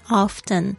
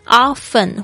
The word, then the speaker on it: often: 好的，我们说常常去微笑， smile often 就是这样说， smile S-M-I-L-E，特别注意一下，之前讲过跟这个 smiled 的发音是非常不一样的， smile 是笑， smell 是闻到的这个味道。 often 或者是 often 都有人说，所以呢看你自己的习惯， often 或 often 都会听到。